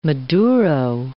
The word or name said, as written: maduro